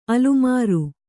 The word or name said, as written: ಅಲುಮಾರು